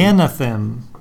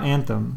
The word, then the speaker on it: Anathem: Yeah, well, it's definitely not anthem. No, because that's missing an A.